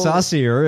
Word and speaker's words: Saucier: S-A-U-C-I-E-R. Oh. Saucier, it might be. – Oh, Saucier! – I'm giving him the French pronunciation.